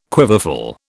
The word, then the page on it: quiverful: British English